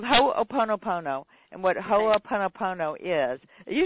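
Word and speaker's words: Ho'oponopono (Hoʻoponopono): and I never pronounce this correctly, but the Ho'oponopono. – Ho'oponopono. – Yes, thank you.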